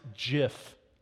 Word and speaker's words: GIF: I have a hard time pronouncing it this way, but apparently it's pronounced GIF, okay?